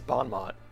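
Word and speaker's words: bon mot: Yeah, we'll move laterally over here, and then we will Balmo the... – What was that weird pronunciation? It's Bon Mott.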